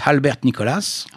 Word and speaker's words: Albert Nicholas: Alors, on va passer à un autre clarinettiste nettement plus fort, qui s'appelle Albert Nicholas. Alors, comme il était de la Louisiane, on va dire Albert Nicholas, on va le prononcer à la française, on peut.